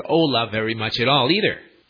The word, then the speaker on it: hola: Well, if you're really bad with names like I am, you can just wave your hand and yell out hola, H-O-L-A, which means hi. It's the universal, casual Spanish greeting. Hola, H-O-L-A. and the H is silent.